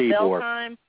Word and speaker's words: Ybor: It's pronounced Ybor. – It is like Eeyore.